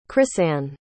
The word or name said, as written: chrisann